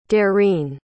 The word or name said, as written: dareen